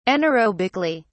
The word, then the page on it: anaerobically: British English